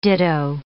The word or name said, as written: ditto